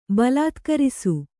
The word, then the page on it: ಬಲಾತ್ಕರಿಸು: balātkarisu